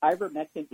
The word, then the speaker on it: ivermectin: I had someone from BGH ask me to ask you about ivermectin. Is that how you pronounce it? – Ivermectin. – Oh, there we go.